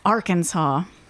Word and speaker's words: Arkansas: It's Arkansas. – Why it's not pronounced Arkansas, I don't know. Because it's literally Kansas with A-R in front of it.